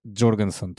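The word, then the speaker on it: Jørgensen: In je raziskal, ne, oziroma, ne vem, on ve, kako se pravilno izgovori Jorgensen, izgovori se Jorgensen, torej kako ima že ime? – Mateo Jorgensen.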